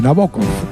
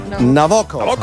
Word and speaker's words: Nabokov: Con una amiga rusa el otro día y me dijo cómo se pronuncia correctamente, Nabokov. – Nabokov.